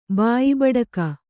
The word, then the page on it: ಬಾಯಿಬೂಟಕ: bāyi būṭaka